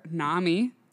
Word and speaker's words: NAMI: She's also, she works for NAMI. – Yes. – Is that how you say it? – Yeah.